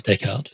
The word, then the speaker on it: Descartes: And now, is it Descartes? Is it just pronounced Descartes? – It is Descartes.